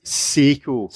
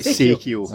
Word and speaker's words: Seiko: Said it wrong. – He said it right. – It's Seiko. – Seiko. – Not Seikio. – No. Seikio. – Not Seiko.